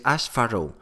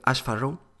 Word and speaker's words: Ash Furrow: Ash Farrow. No sé exactamente cómo se pronuncia. Es Ash Farrow. A-S-H F-U-R-R-O-W. Ash Farrow.